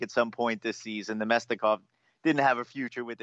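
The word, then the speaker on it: Namestnikov: And we get, you know, Neskinov gets traded. And what's your take on the deal there? I butchered his name. I'm sorry about it. – Nemestikov. Thank you.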